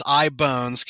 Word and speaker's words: iBones: So I've made it iBones Models, which is a lot of people have said, oh, Ibanez or something like that, like the musical instrument company. But it's iBones because Ian, I, Bones, because my last name is spelled B-O-N-N-E-R.